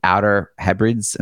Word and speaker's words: Hebrides: Am I saying this right? – Almost Outer Hebrides. – Hebrides. I'm seeing it spelled out next to me, which is why it makes me trying to guess. It's spelled Hebrides. – Yeah. – So Hebrides, but it's Hebrides. – Hebrides. So Outer Hebrides.